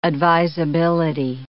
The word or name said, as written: advisability